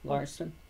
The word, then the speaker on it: Larsen: Well, my real name is Elizabeth Ann Murphy Larson. – Okay. And, Oh, do I have to spell it? It's spelled L-A-R-S-E-N.